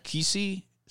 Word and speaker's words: Qissi: Q-I-S-S-I. – Okay. – Kissi, is how you say that, I imagine?